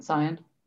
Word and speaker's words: Samhain: You know with Halloween, and you know, is it pronounced Soan? – Soan? – The S-A-M-H-A-I-N. – Yep, Soan.